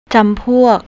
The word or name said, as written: jampouk